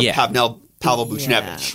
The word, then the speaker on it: Pavel Buchnevich: Pavel Buchnevich. Yeah. Like, I probably butchered that name. – I think you actually nailed it, and I'm shocked, but good job.